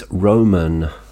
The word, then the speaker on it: Romaan: He's Romaan, uh, spelt R O M A A N, Orona.